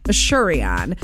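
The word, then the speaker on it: Asurion: Assurion.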